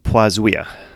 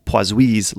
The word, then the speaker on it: Poiseuille: The one I'm settling on is poisuia, poisuia when it's singular. When we talk about the law, Poisui's law, that's how we might pronounce that.